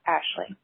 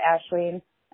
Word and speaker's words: Aisling: I know you're probably getting a million calls about this, but the Irish name that you guys keep saying is Aisling is pronounced Ashley. All right, bye. Hi, I just paused the pod and called about Ashleen, and then 1 second later, after I clicked it again, you corrected it.